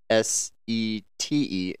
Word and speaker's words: sete: Even though it's S E T E, it's sete. – Yeah, in Brazil they say sete, and uh, in Mozambique we say sete. – Sete. So sete and sete.